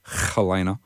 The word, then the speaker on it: Helena: We had My Chemical Romance and their track Helena from Three Cheers for Sweet Revenge. You got to get the sound at the beginning of Helena.